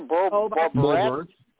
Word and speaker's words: Boebert: Boebert.